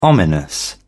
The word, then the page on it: ominous: British English